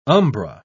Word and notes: umbra: British English

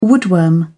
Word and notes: woodworm: British English

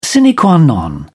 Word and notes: sine qua non: British English

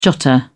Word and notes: jotter: British English

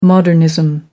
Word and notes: modernism: American English

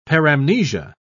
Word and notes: paramnesia: British English

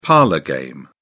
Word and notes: parlour game: British English

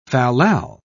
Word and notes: fallal: British English

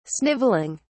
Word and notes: sniveling: British English

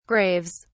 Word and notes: graves: British English

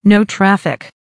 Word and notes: no traffic: American English

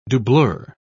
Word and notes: doublure: British English

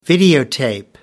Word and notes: videotape: American English

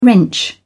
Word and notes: wrench: British English

